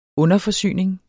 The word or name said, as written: underforsyning